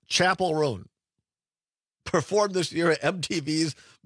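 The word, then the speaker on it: Chappell Roan: I would have also guessed Chappelle based on how it's spelled.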